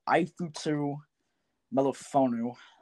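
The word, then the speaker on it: Ifeatu Melifonwu: It is Ifutu Melifonu. That's how you say his name.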